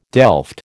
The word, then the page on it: delft: American English